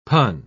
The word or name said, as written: pun